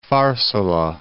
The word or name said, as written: Pharsala